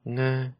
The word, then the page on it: nga: Lwaitel